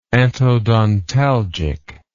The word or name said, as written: antodontalgic